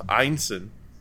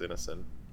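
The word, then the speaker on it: Ineson: according to John, pronounced Einson, uh, but we always read it as Innocent.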